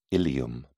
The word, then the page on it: ilium: British English